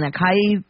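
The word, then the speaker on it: açaí: And you know the Amazonian fruit that pretty much all of us don't really say it right, A-C-A-I. I've been saying acai powder, stuff like that. It's really ah and then sigh and then e, acai. That's how you say it.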